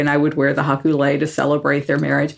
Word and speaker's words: haku lei: A haku lei, is that how you pronounce it? – Haku lei, yeah. – Haku lei. – You know, like a lei that you wear around your neck. So it's haku lei.